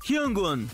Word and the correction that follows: Hyungwon: Ajá. Se pronuncia Hyungwon, ¿verdad? Hyungwon. Esto tal vez es un ah. poco difícil porque este sonido no existe en español. Entonces se pronuncia Hyungwon.